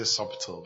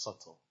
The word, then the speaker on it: subtle: Some people say subtle, but I don't think it's subtle. It's subtle. Okay. It's spelled subtle, but it's pronounced subtle.